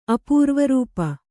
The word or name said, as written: ಅಪೂರ್ವರೂಪ